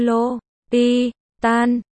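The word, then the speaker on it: L'Hôpital: Trong giải tích, quy tắc Le Hôpital, phát âm như lô, pi, tan,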